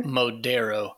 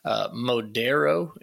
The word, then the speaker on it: Modero: Modero. – So if you're listening to this, you want to support a small family-owned Christian business and get amazing fragrances out of candles, Modero.